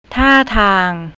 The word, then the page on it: tartang: thai